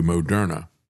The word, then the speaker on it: Moderna: As a matter of fact, the way they initially pronounced their name was mRNA. And then they changed the pronunciation to Moderna.